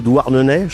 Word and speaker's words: Douarnenez: Commodore est un quintet originaire de Douarnenez, je prononce bien. – T'as bien révisé tes fiches.